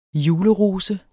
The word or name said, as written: julerose